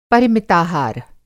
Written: पर

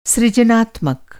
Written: सृजनात्मक

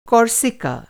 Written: कोर्सिका

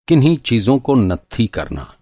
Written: नत्थी

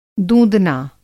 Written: दूँदना

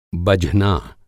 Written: बझना

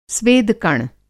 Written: स्वेदकण

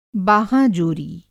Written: बाहाँ जोरी

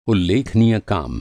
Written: उल्लेखनीय

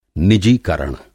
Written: निजीकरण

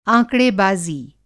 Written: आँकड़ेबाज़ी

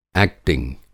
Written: एक्टिंग

